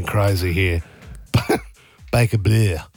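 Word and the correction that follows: Bleu: It's not Baker Blue, is it? – Baker Blue. – Is it Baker Blue? – Yeah, I think it's spelled B-L-U. A French bit on it.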